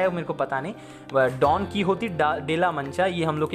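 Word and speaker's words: Don Quixote: वहाँ पे इसका प्रोनाउंसिएशन डॉन की होती है आई गेस और यहाँ पे हम लोग क्विक्जॉटिक भी बोलते हैं तो दोनों प्रोनाउंसिएशन चलता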